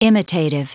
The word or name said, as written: imitative